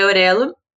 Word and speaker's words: Orelo: É, Orelo, se escreve O-R-E-L-O. Quase dei uma de M's aqui, de, de não conseguir soletar o negócio. Mas é Aurelo.